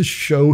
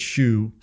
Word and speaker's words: shew: Now, in my King James, it says S-H-E-W. Mm-hmm. And I knew somebody one time that said, well, you got to pronounce it shoe or else you're not really King James. – Well, but I would ask, how do you pronounce S-E-W? – S-E-W? – Yeah.